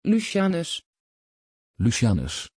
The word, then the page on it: Lucianus: Dutch